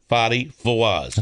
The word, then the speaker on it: Fadi Fawaz: Fadi, F-A-D-I. I hope I'm pronouncing it right. – Right. – Fawaz, F-A-W-A-Z. – Okay. – Fadi Fawaz.